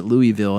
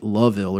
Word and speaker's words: Louisville: No matter how I pronounce it, I usually pronounce it Louisville, and that gets me chewed out. I get a bunch of very angry messages on Twitter when these people hear it. I was told it's like Loville or some shit like that.